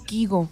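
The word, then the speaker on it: Kygo: ¿Kaigo? ¿Cómo le dicen a este carnal? – A ver. ¿Y si me Kigo? – ¿O él mismo cómo lo pronuncia? – Él se llama a sí mismo Kigo.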